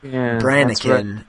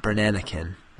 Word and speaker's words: Brannikin: And Chris also said that I mispronounced the cereal idea, that it would be Crackle Note, Brannikin, not Brannanikin, which is an extra syllable in there, yeah.